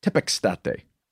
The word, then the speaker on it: tepextate: and there he pronounced it tepes tepextate.